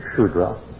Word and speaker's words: Shudra: Where he has common people, the Sanskrit term is Vaishya, and where he has serf, the technical name for the caste is Shudra, often spelled with an S, a dot beneath it, in Sanskrit, S with a dot beneath it, is pronounced as SH.